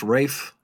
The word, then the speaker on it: Ralph: It's pronounced Rafe, I think. – Well, Yes, I looked it up. – I guess I thought it was just Ray. Maybe it is Rafe. – I looked it up. They show you in a pronunciation thing that it says Rafe.